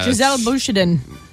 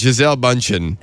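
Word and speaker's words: Gisele Bündchen: Giselle Bündchen. – You said it wrong. – Come on. We all know Bündchen. – You said it wrong. We won't give it to you. It's Giselle Bündchen.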